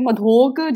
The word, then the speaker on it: Madhok: So my last name is Madhok. Okay, and you're supposed to say it like Madhok. Depends where you are in India. Madhok.